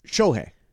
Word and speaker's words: Shohei: Is it Shohei or Shohei? – Shohei. Yeah, it's not Shohei. – S-H-O-H-E-I.